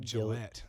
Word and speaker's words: Gillett: G-I-L-L-E-T-T, like the razor, but no E at the end. And then I think my Instagram name's like Chase the Razor 516. – Okay, cool. Yeah, Chase. People get his name wrong all the time, and they're like Gillett. – Or they're like Gillette. They Gillett. Say Gillette and stuff, yeah. And I'm like, it's just Gillett.